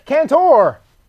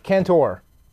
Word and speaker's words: Cantor: Because it's spelled C-A-N-T-O-R. – No, you don't stress the O at the end.